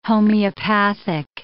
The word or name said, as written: homeopathic